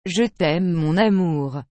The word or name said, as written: je t aime mon amour